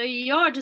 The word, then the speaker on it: yod: So yod is one of these grand configurations. It's a bit of a, you know, yod rhymes with odd.